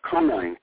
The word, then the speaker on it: Konijn: Well, if we put on our time machine hats here, first of all, the name Coney comes from the Dutch, Konijn, K-O-N-I-J-N, the J is silent.